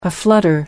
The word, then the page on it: aflutter: American English